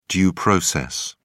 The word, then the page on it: due process: British English